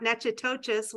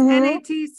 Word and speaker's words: Natchitoches: And I'm going to spell it, and it's Natchitoches, not Natchitoches, which I would have said for years, like, you know, Nancy. But it's Natchitoches.